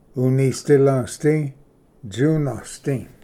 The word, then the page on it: ᎤᏂᏍᏗᎸᏍᏗ ᏧᎾᏍᏗ: unisdilvsdi-tsunasdi